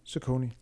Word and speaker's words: Saucony: Fra nu af, så kalder vi det bare Saucony.